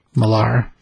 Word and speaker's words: Millar: So that's not Miller, it's pronounced Millar? – Si, senor. Millar.